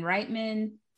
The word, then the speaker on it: Reitman: Steven Spielberg, Jonathan Demme, Terry Gilliam was the author's first choice, Tim Robbins, Ivan Reitman, Rob Reiner, Do I say, I think I messed up Reitman the last time. Did I say it right? – You said it right this time.